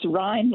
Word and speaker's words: Rhine: It's Rhine, like rhymes with wine.